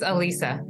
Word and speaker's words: Elisa: No, it's Elisa. Elisa Kuwam.